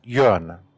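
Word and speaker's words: Jürgen: It's pronounced Jørgen, one syllable.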